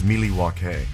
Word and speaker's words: Milwaukee: It's actually pronounced Milwaukee.